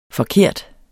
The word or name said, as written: forkert